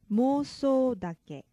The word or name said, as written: mousoudake